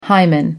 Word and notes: hymen: American English

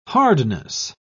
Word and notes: hardness: British English

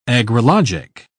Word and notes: agrologic: British English

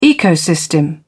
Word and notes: ecosystem: British English

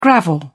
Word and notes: gravel: British English

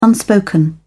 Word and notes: unspoken: British English

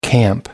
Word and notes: camp: American English

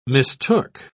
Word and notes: mistook: British English

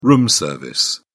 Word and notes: room service: British English

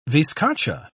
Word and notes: viscacha: British English

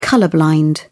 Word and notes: colour blind: British English

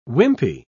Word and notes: wimpy: British English

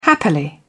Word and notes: happily: British English

